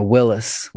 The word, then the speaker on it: WILIS: What I Learned in School, W-I-L-I-S? – Yeah. – Is that kind of how that goes? – Exactly. Yeah, Willis, What I Learned in School.